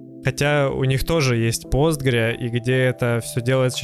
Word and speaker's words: Postgres: Для начала нужно разобраться все-таки Postgres или Postgres. – Правильное произношение Postgres. Она называется PostgreSQL, но сокращенно это Postgres. Это есть комментарий от разрабов, как правильно говорить.